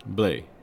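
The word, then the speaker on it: Blais: out there from Team Quebec, the host team, we have uh, Emily Blaise. Blay. Blay. – Blay. Blay. – Uh, I, I, I know. I, my French uh, pronunciation isn't the strongest.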